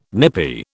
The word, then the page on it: nippy: British English